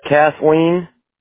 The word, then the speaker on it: Kathleen: It's Kathleen. Giannis. K-A-T-H-L-E-E-N. Last name is spelled L-L-A-N-E-S.